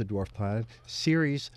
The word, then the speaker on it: Ceres: It's spelled as if it were Sears, like Sears and Roebuck. It's C-E-R-E-S, pronounced Ceres.